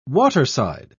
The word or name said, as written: waterside